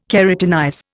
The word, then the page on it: keratinize: Indian